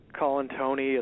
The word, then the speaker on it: Colantuani: It's Colantuani. It's vowel-laden, therefore it's a bit of a challenge for most people to pronounce.